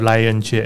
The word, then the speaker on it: Lionche: 啊、呃，我们的粉丝专页如果用英文发音，可能大家比较容易搜寻，叫做 Lionche L I O N C H E 墨西哥旅游探索。